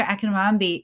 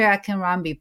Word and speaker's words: Akinronbi: So, Dr. Akinronbi is a psychiatrist joining us from New Jersey. So, Dr. Akinronbi, please introduce yourself to our guests and our listeners.